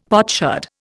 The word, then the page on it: potsherd: Indian